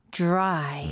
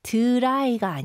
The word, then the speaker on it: dry: Dry. 음흠. 그래서 이거는 dry가 아니고요. – 네. – J 발음 느낌으로 okay. – dry. – Dry. – Yeah. – D-R-D 충돌을 합니다.